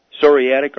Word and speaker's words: psoriatic: But, what she has, I don't know how to pronounce it, but it's P-S-O-R-I-A-T-I-C, arthritis. – Psoriatic arthritis? – Psoriatic, yeah, I guess that's it, yeah.